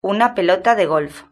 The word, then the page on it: una pelota de golf: European Spanish